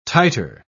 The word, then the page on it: titre: British English